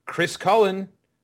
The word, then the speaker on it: Chris Cullen: Speaking of Tim Hardaway, Chris Cullen rhymes with Chris Mullen.